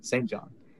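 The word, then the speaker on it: SAINt JHN: Capital S-A-I-N, lowercase t, space, capital J-H-N. St. John.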